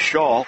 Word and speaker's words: Scholl: It goes against the Redskins, 2 free throws coming for Alex Shaw. Pronounced as if it's S-H-A-W, but spelled S-C-H-O-L-L.